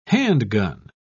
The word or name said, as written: handgun